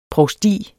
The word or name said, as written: provsti